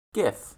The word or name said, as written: gif